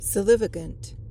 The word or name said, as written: solivagant